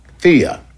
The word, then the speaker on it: Theia: Do you two personally believe in Theia? Is that correct? Am I saying it right? Theia, T-H-E-I-A.